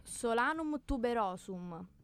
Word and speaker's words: Solanum tuberosum: E vi diciamo innanzitutto che è definita dal, mio, dal nome botanico come Solanum tuberosum.